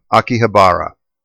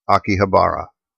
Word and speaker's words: Akihabara: And he said that I'm essentially overworking it, and that in the Japanese it's pronounced just like you see it. So here goes. Akihabara. Akihabara.